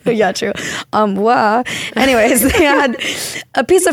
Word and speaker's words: armoire: but my parents had this armoire. Am I saying that right? – Armoire. – Armoire? – Like a soft R, I think.